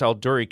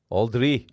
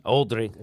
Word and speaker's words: Aldourie: And as they approached, now you're going to have to, I think it's Aldury Castle. Is that the correct pronunciation? A-L-D-O-U-R-I-E. Aldury Castle? – A-L-D-O-U-R-I-E. Aldry. – Aldry. – It sounds like that.